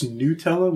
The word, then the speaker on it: Nutella: It's Nutella with the long U sound.